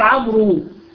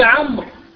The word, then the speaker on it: عمرو: فالواو فقط وضعت للتفرقة بين الاسمين من حيث النطق. عمرو لا يجوز أن تقول عمرو عمرو عمرو بالواو، لا، عمرو عمرو، تمام؟ هو عمرو وليس عمرو.